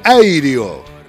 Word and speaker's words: REO: Se escribe reo como si fuera un preso, un recluso. Se pronuncia airio.